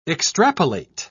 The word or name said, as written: extrapolate